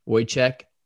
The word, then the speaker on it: Wojtek: W-O-J-T-E-K, pronounced Wojciech. Wojciech Wolski.